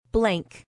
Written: blank

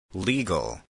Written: legal

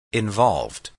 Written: involved

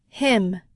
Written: him

